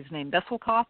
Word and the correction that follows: Bessel van der Kolk: or something. – Bessel van der Kolk.